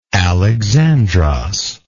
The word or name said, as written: Alexandros